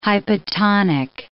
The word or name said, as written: hypotonic